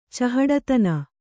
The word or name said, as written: ಚಹಡತನ